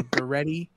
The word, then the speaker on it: Guerrieri: but it's Guerrieri, like Guy Fieri, so Guerrieri.